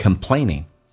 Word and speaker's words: complaining: Stop complaining。 Come 这个单词很多人发成 complaining，不是 con，是 come， complaining 不要发成鼻音。